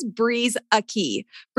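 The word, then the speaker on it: Brueseke: My last name is Breeze Aki. So it's like wind, breeze, Aki.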